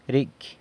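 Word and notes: ricy: San Lucas Quiaviní Zapotec

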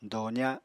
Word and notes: doza: apatani

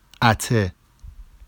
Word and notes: ate: Vafsi

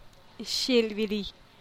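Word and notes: xilbidi: Teotitlán del Valle Zapotec

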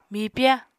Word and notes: mipya: Apatani

